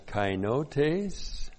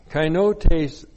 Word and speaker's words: kainotes: Preposition en, in, and then kainotes. Looks like that in the Greek, K-A-I-N-O-T, long E-S, kainotes. Kainotes literally means newness with the connotation of something that is extraordinary.